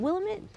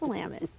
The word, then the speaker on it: Willamette: Willamette. Is it Willamette? Mm-hmm. No, it's Willamette.